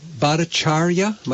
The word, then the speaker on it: Bhattacharya: So one of the op-eds that we're running in today's Vermont Daily Chronicle, Amy, is by Jay Bhattacharya.